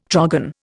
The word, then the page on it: trogon: Indian